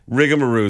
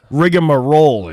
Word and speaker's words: rigmarole: Oh yeah, rigamaroo is a great one. – Rigmarole, it's pronounced.